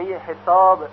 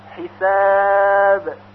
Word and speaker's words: حساب: مثلا به قلقله با در آخر کلمه حساب توجه کنید، حساب، حساب، یعنی اگر به طرف کسره میل پیدا کنه حسابی غلط هست و همچنین به طرف فتحه حساب این هم باز غلط هست، بلکه باید به طور ساکن تلفظ بشه حساب.